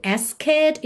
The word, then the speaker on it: asked: A gente vê essa palavra A-S-K-E-D, mas o E não é pronunciado. Então, eu já não digo asked, e sim asked, asked alright?